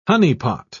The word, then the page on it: honeypot: British English